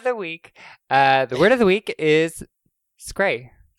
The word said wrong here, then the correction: scry: Oh, it is scry. – Okay, scry. All right.